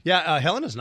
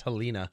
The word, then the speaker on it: Helena: Helena's nice. And I can tell you're not full of it because you pronounced it right. Everyone says Helena.